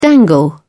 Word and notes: dangle: British English

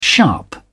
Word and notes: sharp: British English

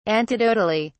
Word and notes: antidotally: British English

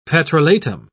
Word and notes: petrolatum: British English

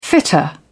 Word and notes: fitter: British English

